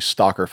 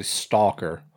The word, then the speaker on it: Stocker: It's actually Stocker Farms, but it's spelled like S-T-O-C-K-E-R. – Oh, okay. I knew it wasn't actually Stalker.